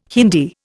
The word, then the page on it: Hindi: Indian